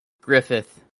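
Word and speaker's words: Gruffudd: It is Welsh. I'm checking multiple sources. It is Yoan. – Okay. – Griffith. – Griffith. – The second source says Griffith.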